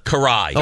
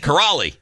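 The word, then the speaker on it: Kiraly: K-R-I-A-L-Y. Karai. – Okay. – There's an L, but it's silence. Because many people would look at that name and think Karali.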